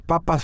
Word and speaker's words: papa: Papá y papa suena igual, pero papá es Papá. – el papá. Depende cómo lo pronuncias.